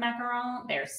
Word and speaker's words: macaron: I'm a huge fan of macaron. If they're good, they're good. – I'm a huge fan of you pronouncing it that way.